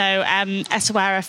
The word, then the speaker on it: Essaouira: So Essaouira, finally, we've got the pronunciation right.